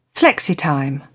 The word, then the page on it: flexitime: British English